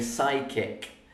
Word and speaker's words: psychic: And psychic actually has two silent letters. The P is silent and the H is silent because we don't say psychic, psychic. We say psychic. It has two silent letters, P and H.